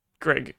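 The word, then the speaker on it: Greig: Gregg, yes, I pronounced it right. That I feel like I almost said Greek again.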